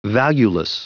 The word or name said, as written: valueless